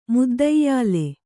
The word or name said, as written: ಮುದ್ದೈಯಾಲೆ